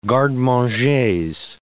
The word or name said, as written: garde-mangers